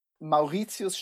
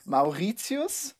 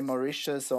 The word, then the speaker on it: Maurizius: Maurizius Staerkle Drux. – Oh boy. Okay, slow a little bit slower. – Maurizius. – Maurizius. – Genau. Yeah. You can also say Mauritius or Maurizio. It doesn't matter.